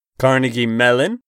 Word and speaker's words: Carnegie Mellon: Carnegie Mellon.